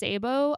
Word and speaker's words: Ceibo: Nemonte is a 33-year-old woman, and she not only co-founded the, I think it's pronounced Cebo Alliance, C-E-I-B-O,